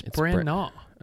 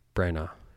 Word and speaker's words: Branagh: Don't pronounce the G-H. It's Branagh. – Okay. Silent G-H. – Yeah. – It's just like Kenneth B-R-A-N-A-W. – Yeah. – Branagh.